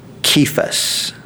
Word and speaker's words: Cephas: You shall be called Cephas," is the way to pronounce it, which is translated a stone.